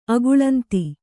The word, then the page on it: ಅಗಳಂತಿ: agaḷanti